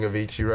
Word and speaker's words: Avicii: Buy everything Avicii right now.